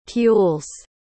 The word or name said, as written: pules